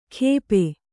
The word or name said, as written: ಖೇಪೆ